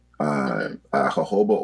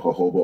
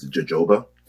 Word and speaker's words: jojoba: So jojoba oil, it usually gets mispronounced as jojoba.